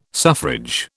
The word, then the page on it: suffrage: British English